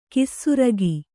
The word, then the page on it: ಖಾಸರೋಗ: khāsā rōga